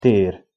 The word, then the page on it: ter: Hungarian